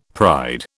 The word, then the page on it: pride: British English